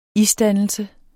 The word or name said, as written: isdannelse